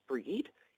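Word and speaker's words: Brigid: Her name is spelled Bridget, but it's pronounced Breed. It means the bride.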